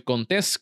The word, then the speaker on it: Contés: Mi nombre es Miguel Contés con acento en la E y nos vemos en la próxima.